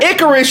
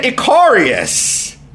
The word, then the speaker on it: Icarus: Like, last night, instead of Icarus, she said Icarius.